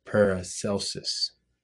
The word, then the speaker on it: Paracelsus: The guy's name is Philippus Areolus Paracelsus. I believe I'm saying that correctly. So tell us what he did. – Paracelsus. I've been pronouncing his name wrong for a long time.